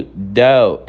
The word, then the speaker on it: though: A pronúncia do though é com a língua entre os dentes, fazendo um som de D. Though, though, ok?